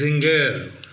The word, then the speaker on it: Singer: That person corrected my pronunciation. It is Zinger.